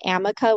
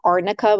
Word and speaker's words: arnica: the lawyer kept pronouncing it amica with an M like Mary instead of arnica with an N like Nancy.